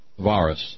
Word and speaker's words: Varus: Because on this day, in the Teutoburg Forest, to the east of the Rhine, a brutal governor-slash-general of the Roman legions, Varus, V-A-R-U-S, you don't pronounce the V as a V, you pronounce it as a W in Latin, got some 20,000 Roman soldiers massacred in the dark and terrifying confines of the Teutoburg Forest.